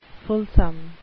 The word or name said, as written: fulsome